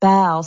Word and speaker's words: bough: the bough, B-O-U-G-H, that would be pronounced bow.